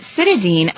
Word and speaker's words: cytidine: Next, it's broken down by the body into the nutrients choline, a B vitamin, and cytidine, a natural molecule involved in cell division. Did you hear how I pronounced that? – Mm-hmm. That was very good.